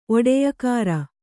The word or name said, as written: ಒಡೆಯಕಾರ